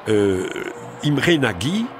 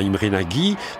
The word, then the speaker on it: Imre Nagy: Donc après ces euphorie, confusion, répression, Imre Nagy, je vais prononcer à la française, hein, Imre Nagy, qui est un leader communiste réformateur et qui avait été écarté en 1955 du pouvoir, prend le pouvoir, forme un nouveau gouvernement.